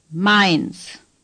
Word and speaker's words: Mainz: Say Mainz. – AI does not occur often. It is pronounced as in the English word my. – Mainz. – The Z is pronounced like TS.